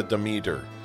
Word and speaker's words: Demeter: Today we are doing The Last Voyage of the Demeter, which is apparently how you pronounce the name of the ship from Dracula.